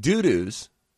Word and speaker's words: Dudus: Or Dudus? It's D-U-D-U-S. We get to choose how we pronounce it. – That's Dudus.